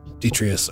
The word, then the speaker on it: detritus: And the there looks like there's also cabinets that have completely fallen apart and other, like, just dust and detritus. Or what was the word? How do you pronounce it? I've only read it. – Yeah. – Detritus. Detritus. – Yeah, you got the word right.